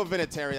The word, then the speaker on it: Vinatieri: I go Vinatieri.